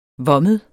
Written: vommet

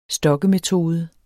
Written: stokkemetode

